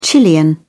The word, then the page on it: chilean: British English